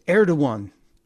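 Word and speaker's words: Erdoğan: It's Erdogan. And why wouldn't somebody have written it phonetically for the president? President Erdogan. It's not to say President Erdogan.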